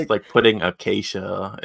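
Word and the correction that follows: Akashia: as the leader in... – Akasha. – You think she's earned the right for me to say her name correctly? – Yes, everyone has. Okay, it's Akasha.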